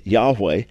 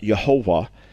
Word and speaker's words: Yahweh: In other words, although I'm confident, I can't say without a doubt, but I'm confident that the original pronunciation of God's name would have been Yahweh and certainly not Jehovah, which then became Jehovah.